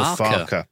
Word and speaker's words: Farke: Daniel Farke, which is what we've been saying, like rhymes with Scott Parker or Peter Parker.